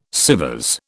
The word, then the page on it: Sèvres: British English